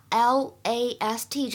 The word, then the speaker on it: last: 在今天这句话的朗读过程当中呢，我们首先注意一下 l a s t 这个单词呢，它在英式英语当中读成 last，last 是有一个长元音的 r，但是在美式英语当中呢，可以读成梅花音的 a，last，last。